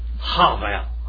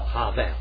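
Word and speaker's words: Havel (הבל): How do you say that in Hebrew? Havel. Havel. All right. Havel. All right. That's it in Hebrew.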